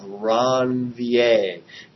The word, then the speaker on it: Ranvier: Notice there are gaps in between the cells, gaps in the myelin sheath called nodes of Ranvier. Yes, that's the correct way to say it because I took French in high school.